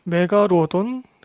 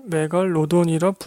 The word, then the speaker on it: 메갈로돈: 메가로돈 감상하셨네요. 메갈로돈을 메갈로돈이라 부르지 못하다니요. 무슨 홍길동도 아니고 슬픕니다. 이럴 거면 차라리 원제목 더 맥으로 하던지, 영화상 나오는 발음도 전부 맥으알 라던인데 메가로돈이라니요.